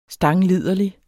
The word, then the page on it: stangliderlig: [ ˈsdɑŋ- ]